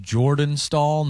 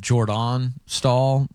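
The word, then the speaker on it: Jordan Staal: I've seen some good tweets saying it's pronounced Jordan Stahl, not Jordan Stahl, is what Gary Bettman's telling him.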